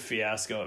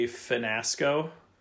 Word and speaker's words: fiasco: I just said fiasco. It made me think of the Bachelor this week. Twice, girls on The Bachelor mispronounced words really badly. Uh-oh. This girl referred to a scenario as a finasco.